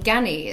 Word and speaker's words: Ganni: Brands that we all want to know, you know, like I think Ganni as well, is that how to say it? – Yes, Ganni?